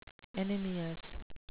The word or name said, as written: anemious